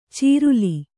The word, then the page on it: ಚೀರಲ: cīrala; dial.